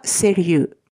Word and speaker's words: sérieux: え男性系の方がセリユ女性系はセリユーズ、セリユーズです。で、まあ、あの、今回ビジネスマン男性なので、純粋セリユ